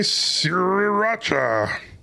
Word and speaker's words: Sriracha: Why are people pronouncing it Sriracha? Because there's an extra R there. There's got to be another R sound.